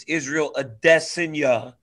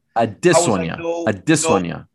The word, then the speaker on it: Adesanya: I've always said, when you were telling me, Daniel, it's Israel Adesanya. – Adesanya.